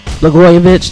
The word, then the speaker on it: Blagojevich: And another thing is, former Illinois Governor Rod Blagojevich, if I'm pronouncing his name correctly, Blagojevich, thank you there, Judah, he